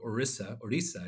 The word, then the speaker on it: Orissa: To go back a little bit, my parents emigrated from a smaller state in India called Orissa is the pronunciation for it, in Eastern India.